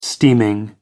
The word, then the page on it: steaming: American English